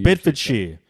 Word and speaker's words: Bedfordshire: Today I'm here from Bedfordshire. Is that how you pronounce it? – You can say it like that. Bedfordshire is the way we Bedfordshire.